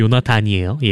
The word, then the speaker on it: 요나단: 다윗과 요나 크크크 요나 아니고 요나단이에요.